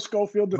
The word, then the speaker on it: Schofield: Michael Schofield III.